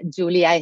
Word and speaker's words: Julia: So it would be at Julia Estacolchic. I can spell it as J-U-L-I-A.